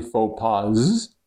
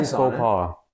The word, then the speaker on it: faux pas: Well, fatherly faux pas. Yeah, but it's French, so it's like a silent S.